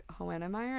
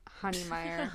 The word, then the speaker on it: Hoenemeyer: Well, then I think people think my last name, when I say honey, they think it's, like, H-O-N-E-Y. Or people think my last name is pronounced, like, Hohenemeyer, and I'm like, it's Honeymeyer. Hohenemeyer.